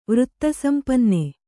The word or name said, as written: ವೃತ್ತಸಂಪನ್ನೆ